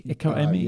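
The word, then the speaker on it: Echo Emi (ἐγώ εἰμι): Echo Emi, is it pronounced in Greek? Echo Emi? – Yes.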